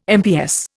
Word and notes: MPS: Indian

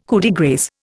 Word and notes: coup de grâce: Indian

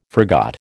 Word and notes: forgot: American English

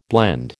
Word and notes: blend: American English

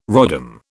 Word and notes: rodham: British English

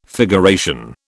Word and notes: figuration: British English

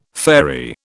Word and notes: fairy: British English

